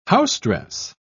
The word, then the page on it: housedress: British English